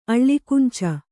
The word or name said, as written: ಅಳ್ಳಿಕುಂಚ